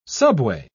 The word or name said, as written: subway